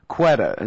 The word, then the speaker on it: Quetta: talking about how they really want to bomb Quetta. Is that how it's pronounced? – Yes.